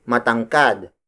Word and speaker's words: matangkad: Tangkad is spelled as T-A-N-G-K-A-D. It means to have a slim and tall body. As an adjective, we say matangkad. Matangkad. Matangkad. Matangkad is spelled as M-A-T-A-N-G-K-A-D. Again, note the ma syllable at the beginning.